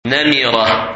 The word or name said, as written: Namira